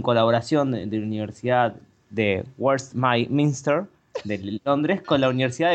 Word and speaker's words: Westminster: No te es de ahí, pero derecha, mejor pronunciado. – Claro, Westminster. Bueno, Kuala Lumpur queda en Malasia y es importante porque toda la población sujeto de este experimento fue malaya.